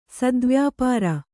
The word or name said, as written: ಸದ್ವ್ಯಾಪಾರ